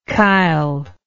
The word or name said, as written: kyle